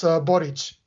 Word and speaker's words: Boric: It's kind of like Mori Povich. It's Boric.